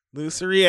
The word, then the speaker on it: lusory: Lusory attitude.